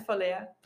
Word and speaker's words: Valea: Yeah, it's Valea.